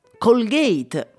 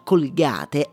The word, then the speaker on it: Colgate: Quindi direi che per seguire la pronuncia originale sarebbe da dire all'inglese Colgate, ma in Italia la pronuncia Colgate è talmente diffusa che la stessa azienda la utilizza nelle campagne pubblicitarie. Quindi direi che possiamo tranquillamente utilizzarla anche noi.